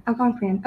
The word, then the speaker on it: Algonquian: Algonquian? – Yeah, Algonquian.